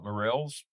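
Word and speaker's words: Mirels: You talked about Morrell's. Did I say that correctly? Morrell's classification. – Yep.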